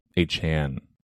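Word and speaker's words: Achane: I mean, I'm hoping, especially since I have Waddle and A-Chain, that they can keep something actually going. Hopefully, not this week, but I think it's actually A-Chan, from what I was hearing from the 4chan, correct pronunciation.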